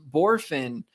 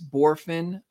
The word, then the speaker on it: Bohrofen: It's pronounced Borfin. However, it is spelt B-O-H-R-O-F-E-N. So it looks like three syllables and it's spelt a little weird, but it's Jace Borfin. Again, that's B-O-H-R-O-F-E-N.